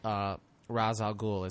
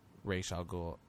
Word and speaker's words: Ra's al Ghul: One of the things that bothered me most after leaving the movie was when he tells Ra's al Ghul, as he pronounces it, though it's Ra's al Ghul otherwise.